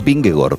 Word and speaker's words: Vingegaard: La doble A en los idiomas escandinavos se pronuncia como una O. Es decir, el ciclista danés Vingegaard es Vingegaard, por raro que suene.